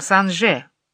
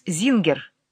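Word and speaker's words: Singer: Патрик родился от второго брака Дункан с Парисом Санже, он же Лоенгрин. Так звучала фамилия Зингер по-французски. Парис Зингер, так произносится эта фамилия по-русски.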